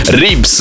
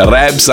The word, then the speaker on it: Ribs: Ribs ho scoperto non si dice Rebs anche se sono italiani insieme a Rave Radio Radio.